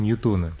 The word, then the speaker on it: Ньютон: И можно сказать, что созданы какие-то новые законы в области физики, которые позволяют лучшим образом объяснить мир, чем это делали в эпоху Ньютона. Теперь почему-то стали говорить еще Ньютона. Кажется мне, что все-таки это неправильное произношение. – Произношение неправильное, действительно, Ньютон.